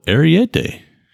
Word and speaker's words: Ariete: It's the CIO Ariete.